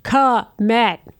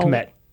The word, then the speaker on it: Kmet: Or as I wanted to call him, like, absolutely two syllables, Cole Kmet. No. – Kmet.